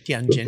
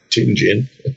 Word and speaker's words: Tianjin: Tianjin. – Pronounced Tianjin.